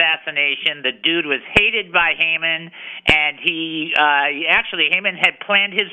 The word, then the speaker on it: Haman: We say Haman. That's how you say it.